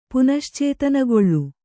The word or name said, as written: ಪುನಶ್ಚೇತನಗೊಳ್ಳು